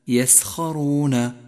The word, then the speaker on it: يسخرون: يسخرون انطقه بشكل صحيح، فهو حرف مفخم مفتوح.